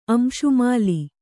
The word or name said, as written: ಅಂಶುಮಾಲಿ